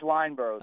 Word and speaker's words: Lineboro: Is it Lineboro? – It is Lineboro. The D is silent.